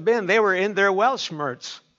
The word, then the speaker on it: Weltschmerz: It's spelt W-E-L-T. S-C-H-M-E-R-Z. Anybody pronounce that? Weltschmerz. Weltschmerz. W-E-L-T-S-C-H-M-E-R-Z.